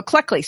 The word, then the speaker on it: Cleckley: His name is Hervé Milton Checkley. Oh, Cleckley. See, I always say it wrong. C-L-E-C-K-L-E-Y. He wrote the most famous book called Mask of Sanity, okay?